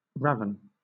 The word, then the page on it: raven: British English